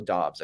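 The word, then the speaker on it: Doubs: way before someone like Romeo Dubs, even later. Or Romeo Dobbs, I should say. He's changed his pronunciation of his name from what they were calling him in college.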